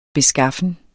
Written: beskaffen

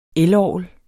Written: elorgel

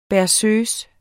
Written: berceuse

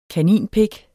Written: kaninpik

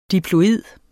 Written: diploid

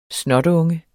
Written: snotunge

